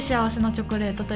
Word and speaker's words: 幸福のチョコレート: うん、えっと幸福のチョコレートと書いて幸せのチョコレートと読みます。